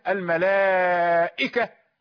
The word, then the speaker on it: الملائكة: الملائكة، لا تنطق بالهمزة، الملائكة كما نفعل، وهي حرف شديد، إذا نطقت بالهمزة ظهر صوتها، لكن هنا لا يظهر صوتها. الملائكة، يعني لا تكون ياء ولا هاء ولا شيء من هذا، وإنما تسهيل بين.